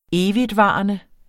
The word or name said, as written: evigtvarende